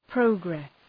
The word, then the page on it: progress: prə’gres